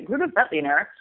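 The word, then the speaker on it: Grüner Veltliner: Um, I think that so if you okay, so you can say Gruner Veltliner. The the V is like an F, right? So you could say Gruner Veltliner.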